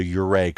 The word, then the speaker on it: Ouray: And the section that we drove is sort of the most popular section that goes from Durango, Colorado, to Ouray, Colorado. And I am saying Ouray right. It's spelled O-U-R-A-Y. I called the Chamber of Commerce to make sure I'm saying it right. It is Ouray.